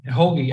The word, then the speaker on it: Rogue: De onde vem esse nome Rock Ferry? Se é pronto, que eu estou pronunciando corretamente. – quase um pouquinho. É Rogue Ferry, quase, na verdade. Rogue.